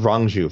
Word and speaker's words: Rong Zhu: Uh, uh, Zhu Rong versus. I keep mispronouncing this guy's name. I'm, I'm going to go with Rong Zhu from now on.